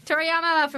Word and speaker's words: Toriyama: Toriyama from Viz Media.